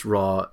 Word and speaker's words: Ra: Ra, pronounced Ra, Egypt pagan god.